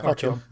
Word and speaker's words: Artyom: I found it problematic that the game couldn't decide whether Artyom. Um, I do, sorry, how do you pronounce that? – Artyom. Yeah, that's it.